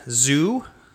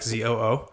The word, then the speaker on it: Zhu: Um, you have Adrian Zhu ZHU, not like ZOO, uh, playing Tron with Thragtusk and a World Breaker in the deck.